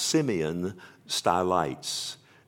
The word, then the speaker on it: Simeon Stylites: and I'll probably not pronounce his name correctly, I believe it's pronounced Simeon Stylites.